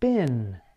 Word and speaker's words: been: These include been, the past participle of the verb be. Although it is spelled with an EE, Americans pronounce it as I.